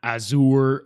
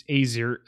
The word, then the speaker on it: Azure: It's Azure. It's not Azure or a, a Azure.